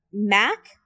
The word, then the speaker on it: Mak: Mac? – Yes. – Mac, right? So we have two Marks and a Mac, which is spelled M-A-K. Mm-hmm. So it's just like Mark without the R.